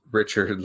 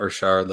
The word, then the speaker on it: Richard: Dustin says it's Richard, like Americans say it, not Richard, like he's a member of the Montreal Canadiens.